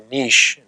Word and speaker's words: niche: That's called a niche or a niche. Both pronunciations are acceptable in English.